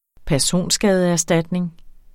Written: personskadeerstatning

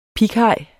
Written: pighaj

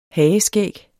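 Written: HAGESKÆGGET

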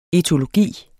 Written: etologi

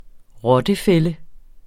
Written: rottefælde

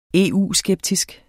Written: EU-skeptisk

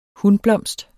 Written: hunblomst

